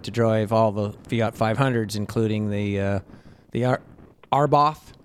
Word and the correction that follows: Abarth: An expert told me how to pronounce it. It's called Abarth.